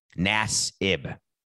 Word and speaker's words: Nassib: Nassib.